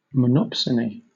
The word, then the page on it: monopsony: British English